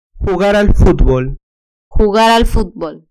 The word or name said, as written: jugar al futbol